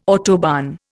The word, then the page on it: autobahn: Indian